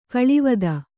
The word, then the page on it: ಕಳಾವತಿ: kaḷāvati